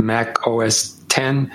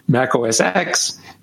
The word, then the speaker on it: Mac OS X: And because Apple likes to toy with us, you know, they toyed with us with Mac OS 10 with an X, and then the secret handshake and decoder ring explanation was, no, you pronounce it 10. And if you're in the know, then you say 10.